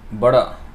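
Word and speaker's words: Bada (বড়): So actually it is Bada, B A D A. So that is the pronunciation. I think the way it is um, written in the Roman transliteration, the, the original way is B O R and top of the R there is a dot and then O, but I think we don't follow that much.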